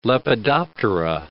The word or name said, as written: Lepidoptera